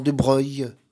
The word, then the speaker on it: Broglie: Le mandat de l'accordéoniste débutait par l'assassinat de son ami Jean de Breuil. De Broglie, comme disait Coluche, c'est des noms de faucheton, ça ne se prononce pas comme ça s'écrit.